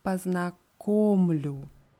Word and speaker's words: познакомлю: Und bei dieser Form ja познакомлю ist für uns die Betonung wichtig. Das Verb wird hier betont auf dem Stamm. Die Betonung ist auf der dritten Silbe auf dem O. Ja познакомлю.